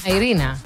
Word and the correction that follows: Irene Cara: No, Irene Cara.